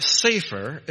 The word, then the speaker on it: sefer: If you pronounce this word sefer, it means a book.